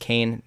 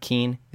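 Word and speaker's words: Kean: And that, of course, is that the young player from Juventus, his last name is pronounced Kane, not Keen.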